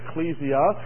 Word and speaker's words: ekklesia: In the Greek, you know the word is ekklesia, or some pronounce it ecclesia, and it's really a compound of two words, ek and kaleo, which means a calling out.